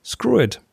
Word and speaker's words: SKRWT: It's S-K-R-W-T. And okay. I've watched a video of the guys who make it. They pronounce it screw it.